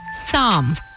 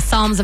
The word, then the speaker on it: Psalm: I don't even think you pronounce the L. It's just Psalm.